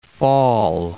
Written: Fall River

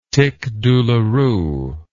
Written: tic douloureux

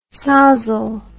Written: sozzle

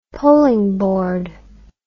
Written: polingboard

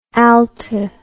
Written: alte

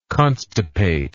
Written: constipate

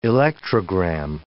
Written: electrogram

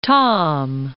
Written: Tom'

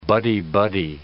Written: buddy-buddy

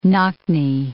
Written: knock-knee

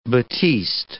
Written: batiste

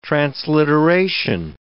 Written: transliteration

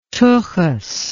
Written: tuchis

